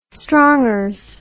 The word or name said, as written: strongers